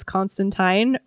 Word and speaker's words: Constantine: Because in some comics, he says it's Constantine, rhymes with whatever. – Clementine, you know, because he may be named after the real emperor or king or whatever, Constantine.